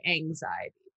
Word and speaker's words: Anziety: It's not anxiety, but it is. It's anxiety. There's just no X and there's a Z. Anyway, it's by Logic. Your face, Kyle. You're like... It's spelled A-N-Z-I-E-T-Y, anxiety, but there's no.